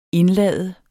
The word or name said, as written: indlade